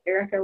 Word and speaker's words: Erika: So you're talking to me, Erika Gimbel, and you can spell that with a K. That's Erika with a K in there, not a C or a CK, just, just K.